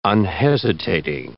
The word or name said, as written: unhesitating